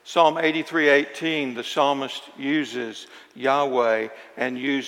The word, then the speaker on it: Yahweh: We pronounce it Yahweh. A few years back, they pronounced it Jehovah.